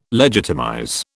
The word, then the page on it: legitimize: British English